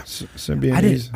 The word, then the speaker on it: Symbionese: How do you pronounce it? Symbionese? – Yeah. – Symbionese.